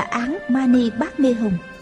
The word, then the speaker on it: Án Mani Bát Mê Hồng: Người ta nói người niệm sai rồi, chú ấy phải đọc là Án Mani Bát Mê Hồng.